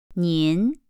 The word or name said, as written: nin